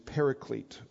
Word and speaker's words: paraclete: The term itself is a term parakletos, or sometimes pronounced in the English paraclete,